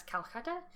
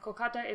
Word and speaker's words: Kolkata: But anyway, so Emperor Aurangzeb, who ruled from 1658 to 1707, allows the East India Company to establish a base in Kolkata, which some listeners may hear as Calcutta, but as an Anglicization, and from what I understand, Kolkata is the right way to pronounce it, and the sensitive way, because more British imperialism, am I right? – Yeah.